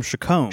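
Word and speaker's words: Chacom: They got pipes from like Lee Eric, Rob E, unique pipes by and I always mispronounce it. – No, it's the Father of the Flame pipe from Chacombe. – Chacombe.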